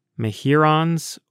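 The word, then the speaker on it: Mehuron's: And so, me-hyur-ons it is. Me-hyur-on, as in Lake Huron.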